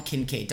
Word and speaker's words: Kincaid: Dalton Kincaid.